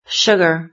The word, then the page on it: sugar: British English